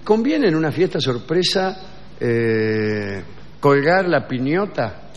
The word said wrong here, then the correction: piñata: La piñata. – ¿La piñata es? – Sí, la piñata. – ¿No se dice piñota? – No.